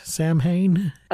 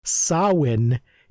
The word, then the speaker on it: Samhain: I think Sam is meant to be named for Sam Hain, which is not pronounced Sam Hain. Okay. Samhain is an old Celtic pagan festival that Halloween sort of absorbed, got absorbed into uh-huh. All Hallows Eve. So a lot of our spooky crap comes from Samhain, which is spelled Samhain but pronounced Samhain because it's Gaelic and nothing is spelled like it sounds.